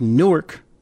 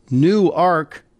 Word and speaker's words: Newark: In this post, Katherine Dettwyler of Newark, Delaware, I know to people in New Jersey and New York it looks like Newark, but down here in Delaware it's pronounced Newark.